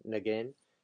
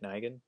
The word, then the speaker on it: Nguyen: I like Dustin Nguyen. I probably said that wrong. – Isn't it Nguyen? – Nguyen, Nguyen. And also, I'm a kind of a sucker for watercolors lately.